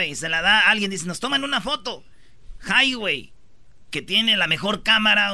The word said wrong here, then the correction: Huawei: ¿Cómo se dice? Huawei. – Huawei. Nosotros lo conocemos como Highway, güey, ya.